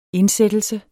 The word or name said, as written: indsættelse